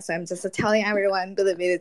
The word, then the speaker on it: Bilibili: It's pronounced as Bilibili. Yeah, Bilibili. – Billy yeah, there's more of an E in there. More of an E. Too much of the I.